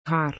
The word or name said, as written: haar